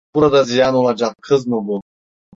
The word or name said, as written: ziyan